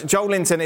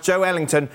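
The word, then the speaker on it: Joelinton: Joe Linton is pronounced Joe Ellington. It's not pronounced Joe Linton.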